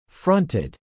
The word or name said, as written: fronted